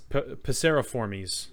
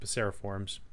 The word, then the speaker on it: passeriformes: Like Latin doesn't have the silent vowels as far as I know. So you pronounce all the vowels. It's Primates, just like it's like Formes Anything that's that has the formies at the end. It's passeriformes, not passeriforms for birds.